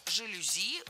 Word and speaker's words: жалюзи: Поэтому жалюзи ударяем последний слог.